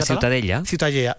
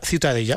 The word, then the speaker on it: Ciutadella: ¿cómo se pronuncia? – La Ciutadella. – Ciutadella. Bueno, pues Ciutadella también. Bien, en castellano. – Ciudadela, sí.